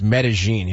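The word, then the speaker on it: Medellín: Medellin, yeah. And the guy who... It is pronounced Medellin. – Is it? – Yeah, they mispronounce it on Entourage for seasons.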